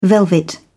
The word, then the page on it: velvet: British English